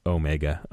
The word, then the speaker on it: Omega: Like, it's Omega, okay, everyone? – Yeah. – Pronounce it correctly.